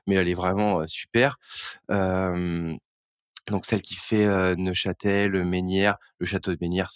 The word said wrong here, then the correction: Neufchâtel: donc, enfin c'est Neufchâtel en vrai. Il faut il faut prononcer le F.